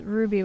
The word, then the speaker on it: RWBY: I have, So this is Ruby, which I think you've talked about briefly on the show, but for our listeners, it's spelled R-W-B-Y.